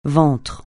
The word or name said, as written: ventre